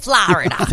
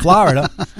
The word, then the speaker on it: Florida: Florida. – Florida.